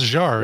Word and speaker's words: Jarre: It's Jarre, isn't it? Like Jean-Michel Jarre? Because it's spelled the same way.